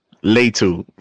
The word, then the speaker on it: Latu: I have Latu, if I'm saying it correctly. Latu, edge from UCLA going to It's Latu, first of all.